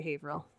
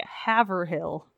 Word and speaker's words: Haverhill: I also had to have my friend Rick tell me how to say Haverhill, so, because he's from New Hampshire. It's spelled like Haverhill. – Oh yeah. – And there's a Haverhill, Massachusetts as well.